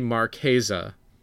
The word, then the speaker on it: Marchesa: And she's like, it's Marchesa. I will bet you. And long story short, I lost the bet. It is definitely Marchesa. And I did not win that conversation. So for everybody, if you are talking about Queen Marchesa, it is actually Marchesa.